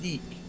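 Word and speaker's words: DIC: It's one of those early Dick series. – Well, I didn't hear it. – Actually, it's Sunbow. – I thought it was Dick. Isn't Dick Sunbow? – Nope. – It's pronounced Deek.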